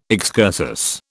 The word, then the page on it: excursus: British English